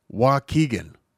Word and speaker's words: Waukegan: And then you pronounce it and you're like, well, it's W-A-U-K-E. Yes, because key's a letter. W-A-U-K-E-G-A-N. And I'm like, what? Because there's very few words that I know offhand that have a U as the third letter. And so I was like, how do you pronounce? Oh, Waukegan. Okay, that makes sense.